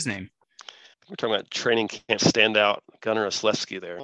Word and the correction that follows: Olszewski: You said it wrong though. He stepped right into it. yeah. Ugh. – You put an extra L in there. – Gunnar Olszewski. Olszewski. Olszewski. Yeah.